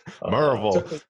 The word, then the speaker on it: Maryville: When I finished up a Master of Divinity in Biblical Counseling in 2004, we came directly here to Maryville, Tennessee. You got to say that right. Maryville is one way to say it, but if you live here for very long, you got to say Maryville. – Maryville.